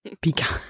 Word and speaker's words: Picard: Da müssen wir jetzt überhaupt erstmal anfangen, wie wir überhaupt Picard aussprechen. Das gute deutsche Picard Pika.